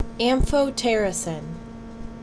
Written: Amphotericin B

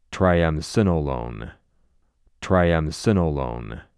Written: Triamcinolone

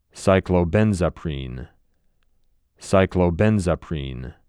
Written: Cyclobenzaprine

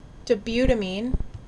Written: DOBUTamine

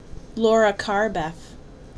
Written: Loracarbef

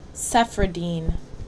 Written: Cefradine

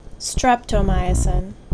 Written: Streptomycin

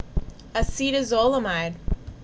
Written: AcetaZOLAMIDE

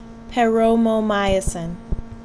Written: Paromomycin

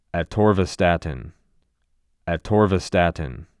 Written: Atorvastatin

